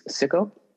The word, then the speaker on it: Sico: So the robot's name in the movie is Sicko. That's spelled S-I-C-O.